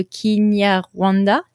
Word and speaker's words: Kinyarwanda: Moi, j'avais cru comprendre selon Google que la langue principale, c'était le Kinyarwanda, si je prononce bien.